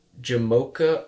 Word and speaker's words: Jamocha: Jamocha almond fudge and Heath chocolate crust. Also, I just want to point out, I didn't mispronounce Jamaica. It is Jamocha.